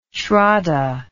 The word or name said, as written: shraddha